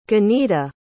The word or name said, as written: ganieda